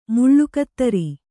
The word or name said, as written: ಮುಳ್ಳುಕತ್ತರಿ